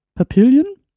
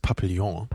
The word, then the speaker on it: Papillon: Da haben wir von Dongal äh, Papillion, spricht man das aus? Ich glaube, es heißt Papillon.